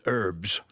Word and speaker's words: herbs: And the Americans borrowed it, they call it herbs. – Yes. – They don't pronounce the H.